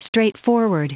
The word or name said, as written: straightforward